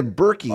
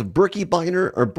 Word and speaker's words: Birkebeiner: So yeah, Birkebeiner. Some people say Berkey. Oh. I saw it's Birkebeiner, but I think most people here pronounce it Birkebeiner.